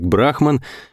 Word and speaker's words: Брахман: Кроме меня и Гарри Поворотова, в той же самой камере находились бывший военный летчик Брахман, это была его настоящая фамилия, ударение на первую «А», а звали Брахмана Георгий Николаевич, можно Жора.